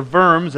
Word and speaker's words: Worms: And so on April 18th of 1521, at the Diet of Worms, or Worms as it's pronounced in German, Luther was called before the Emperor Charles V, and he was asked to retract all of his writings.